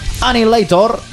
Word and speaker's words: Annihilator: I bueno, continuem ara amb el Beast of Thrash Metal Edition Pastoral L'Ovella Negra amb el super super super duper Annihilator. No sé com es pronuncia, sí, Annihilator o Annihilator, i no es pronuncia l'H pel mig.